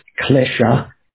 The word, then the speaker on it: klesha: So in Sanskrit, they say it's pronounced klesha. In Pali, I think, maybe some of you know klesha. Maybe there's an added syllable there.